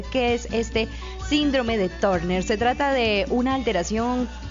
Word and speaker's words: Turner: Síndrome de Turner, que en realidad en inglés se pronuncia Turner.